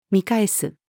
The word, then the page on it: 見返す: female